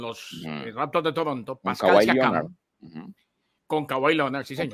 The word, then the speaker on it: Siakam: ¿Siakam? – Sí, Siakam, Pascal Siakam, claro.